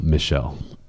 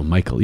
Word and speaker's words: Michel: Do not say, Father Virgil Michel. – Oof. Oh, what do you say? – His name is Virgil Michael, even though it's spelled M-I-C-H-E-L.